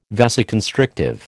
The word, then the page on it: vasoconstrictive: American English